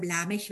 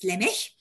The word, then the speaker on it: Lemech: Am Ende von Vers 18 heißt er nur deshalb Lamech, weil das ein Sof-Pasuk ist. Also das ist die Betonung am Ende des Satzes. Der heißt Lemech.